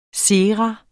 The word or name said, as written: SERAENE